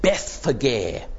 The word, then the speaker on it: Bethphage: They come to the little village of Bethphage. That's how the word in the Greek New Testament at least would be pronounced. Don't know how it's pronounced in Aramaic.